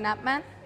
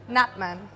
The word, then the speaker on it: Knapman: Charlene, how do I say your full name? – Charlene Napman. – There you go. – Charlene who? – Napman. – Napma. – Yeah. Napman. K-N-A-P-M-A-N.